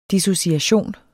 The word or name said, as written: dissociation